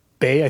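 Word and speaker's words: Weil: Simone, it's Bay. I think it's actually how it's pronounced in Alsatian, but it's W-E-I-L.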